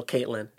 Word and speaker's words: Ktlyn: Her name, she goes by K-T-L-Y-N. That's how it's spelled. So Caitlyn. And she is a rapper.